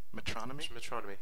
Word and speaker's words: Metronomy: Metronomy. Yeah. They say it in the live album, so I know I'm right.